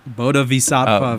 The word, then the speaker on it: Bodhisattva: Bodhisattva, very nice.